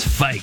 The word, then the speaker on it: Fike: Dominic Fike. It's Fike. You know, rhymes with bike.